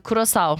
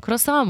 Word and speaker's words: Curaçao: Diyoruz ki mesela Almanya, Curaçao. Curaçao muydu? – Curaçao. – Nasıl okunuyor bu ya? – Curaçao diye bir...